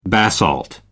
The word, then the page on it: basalt: American English